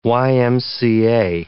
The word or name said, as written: YMCA